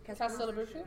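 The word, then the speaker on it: Castelobruxo: Castelabruxu? – Yep, that's it.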